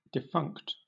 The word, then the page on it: defunct: British English